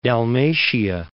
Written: Dalmatia

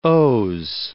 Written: os

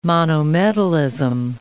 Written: monometallism

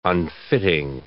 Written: unfitting

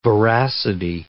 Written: voracity

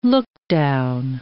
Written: lookdown

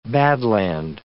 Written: badland

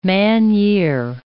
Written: man-year

